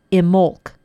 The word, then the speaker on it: Imbolc: It was called Imolc. If you want to look that up, it's spelled I-M-B-O-L-C.